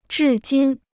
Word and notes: 至今: zhì jīn

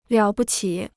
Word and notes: 了不起: liǎo bu qǐ

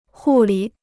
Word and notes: 护理: hù lǐ

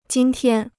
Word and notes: 今天: jīn tiān

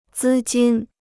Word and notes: 资金: zī jīn

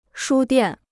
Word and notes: 书店: shū diàn

